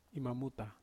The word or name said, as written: mamuta